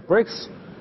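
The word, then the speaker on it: BRICS: 和英文里的 break 就砖的那个意思，发音是一样的。到后来南非加入进来呢，南非第一个打头字母是 S，就变成 bricks，这个等于是砖的复数。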